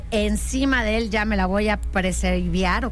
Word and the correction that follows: pre-save: o ¿cómo se dice? – Pre-save. Sí, pre-save.